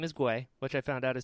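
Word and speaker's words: Gui (龟): It's it's spelled G U I, pronounced Gui, and it means turtle yeah, yeah.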